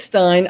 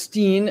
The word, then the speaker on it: Stein: But just for the record, I thought I'd mention it's Stein. I don't know why it's Stein, really. So in the United States, S-T-E-I-N is usually pronounced Steen, and I don't know why.